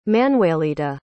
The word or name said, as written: Manuelita